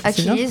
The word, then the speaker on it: Achilles: Ça se prononce Achilles, un truc comme ça. – Ouais, Achilles. – Achilles, c'est bien.